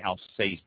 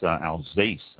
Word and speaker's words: Alsace: It is, I say Alsace, pronouncing the S very distinctly, and I was corrected 3 or 4 times in all the stuff I was reading that it's Alsace, with pronounced the S as a Z. So it's A-L-S-A-C-E, actually pronounced A-L-Z-A-S-S, Alsace.